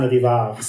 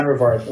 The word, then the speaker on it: Rivard: Is it Rivard or Rivard? How, how should I say it, Stephane? Both, both are correct. Stephane Rivard. Stephane Rivard works very well.